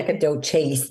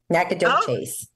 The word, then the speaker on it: Nacogdoches: Yeah, Nacogdoches, they say. Nacogdoches.